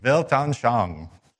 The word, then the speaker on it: Weltanschauung: I'm really nervous about trying to pronounce this because I know Carl's in the room, but it's a Weltanschauung. How did I do? Close? – Weltanschauung. – Okay. Thank you very much.